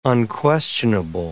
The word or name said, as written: unquestionable